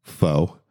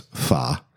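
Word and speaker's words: phở: And then lastly, we did a rendition of what's called, most people call it pho, but it's pha.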